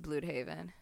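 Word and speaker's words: Blüdhaven: It is pronounced Bloodhaven, sadly. – Blood. – I like Bloodhaven better, but technically it's Bloodhaven.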